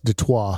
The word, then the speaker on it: Detroit: It's pronounced Detroit. – Oh, is it? – Yes. – I didn't know that. It's French.